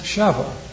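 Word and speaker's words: Sheva (שבע): Just because that word Sheba, pronounced Sheva, like our English word seven.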